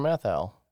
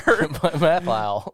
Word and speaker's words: Matthau: It kind of sounded like you put an L in there. – Mathile.